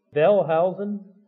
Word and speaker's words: Wellhausen: The name documentary hypothesis, or it's sometimes given a man's name, Wellhausen, Julius Wellhausen. We call it Wellhausenism or Wellhausenism. Usually, I guess we anglicize it a little bit, but the W is pronounced like a V.